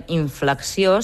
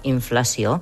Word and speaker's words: inflació: No diem inflexió, sinó inflació, que és el contrari de la deflació.